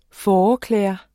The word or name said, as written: fåreklæder